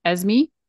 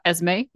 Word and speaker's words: Esme: Tessa Thompson plays Esme, not Esme, Esme.